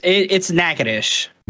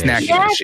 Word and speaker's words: Nacogdoches: It's Nacogdoches. – It's Nacogdoches, yeah. There we go. – Nacogdoches. I've heard this one. – 100% does not sound like it's spelled Nacogdoches.